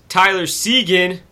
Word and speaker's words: Seguin: Nate McKinnon, Tyler Seguin.